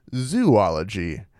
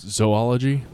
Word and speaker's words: zoology: Everyone except me, it seems like, pronounces it zoology. – It's zoology? – I would say so. It comes from the Greek word zoon, which means animal.